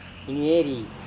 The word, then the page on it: inieri: Serewen